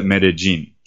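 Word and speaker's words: Medellín: Anytime you have a double L in this region, in this accent of the people that live here, they pronounce it as a J sound rather than a L, like a Y sound, like okay. In classic Spanish. So they call it Medellin.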